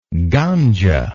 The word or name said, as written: gandzha